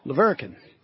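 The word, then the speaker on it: La Verkin: And ever since he schooled me on how to say the name of his school, Leverkin, like Hurricane High School in Leverkin, Utah.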